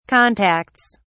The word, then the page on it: contacts: British English